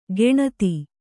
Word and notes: ಗೆಣತಿ: geṇati

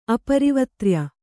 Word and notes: ಅಪರಿವತ್ರ್ಯ: Kannada

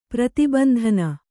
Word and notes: ಪ್ರತಿಬಂಧನ: prati bandhana; kannada

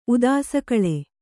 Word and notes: ಉದಾಸಕಳೆ: Kannada

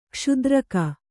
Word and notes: ಕ್ಷುದ್ರಕ: Kannada

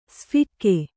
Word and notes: ಸ್ಫಿಕ್ಕೆ: Kannada

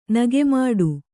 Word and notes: ನಾಗಮೋಡಿ: Kannada

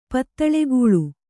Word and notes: ಪದುಳಗೊಳು: paduḷagoḷu

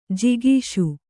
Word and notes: ಜಾಗಿಸು: jāgisu

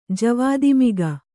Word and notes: ಜವಾದಿಮಿಗ: javādi miga

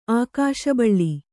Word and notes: ಆಕಾಶಬಳ್ಳಿ: ākāśa baḷḷi

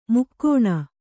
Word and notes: ಮೈಗಣ್: kannada

